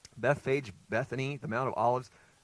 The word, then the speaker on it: Bethphage: And if you have a different pronunciation for that word, I looked it up a few years ago, and I think it's technically Bethphage, and that's just such a weird thing. I'm going to say Bethphage.